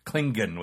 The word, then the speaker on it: Klingon: Still Klingon with the hard G.